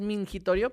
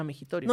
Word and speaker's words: mingitorio: Sí, siento que la palabra correcta es mingitorio, pero también me gusta más cómo se escucha mijitorio.